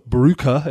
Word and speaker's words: Brugge: Group F, we've got Zenit St. Petersburg, Borussia Dortmund, Lazio, and Club Brugge, is how I believe you say it properly.